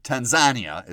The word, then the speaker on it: Tanzania: Today's story is about a man from Tanzania, or Tanzania as he would pronounce it.